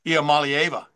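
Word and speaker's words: Iamaleava: Iamalieva.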